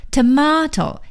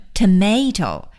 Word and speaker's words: tomato: T-O-M-A-T-O, tomato, 西红柿。 Tomato, 美音的发音呢是念成 tomato, T-O-M-A-T-O, tomato。所以 tomato 和 tomato 都是对的。